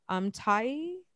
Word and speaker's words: Tuivasa: Tuivasa. – Tuivasa.